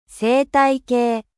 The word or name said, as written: ecosysteme